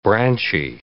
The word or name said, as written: branchy